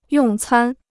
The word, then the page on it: 用餐: yòng cān